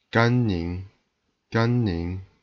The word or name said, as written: Gan Ning